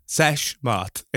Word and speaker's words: sesh moth: Like, have you heard of the phrase "seth moth"? Sesh moth. Sorry, I've said that wrong.